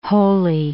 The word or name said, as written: Holy Island